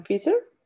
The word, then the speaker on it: Pfizer: La otra que es em, la que están poniendo acá en Colombia, que es la Pfizer, ¿Cómo se pronuncia eso? – Pfizer, Pfizer.